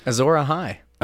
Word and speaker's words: Azor Ahai: Azor Ahai.